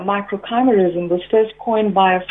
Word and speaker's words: microchimerism: My pronunciation of your science, microchimerism, and I said microchimerism. That had a little bit more romantic feel to it to me, but microchimerism.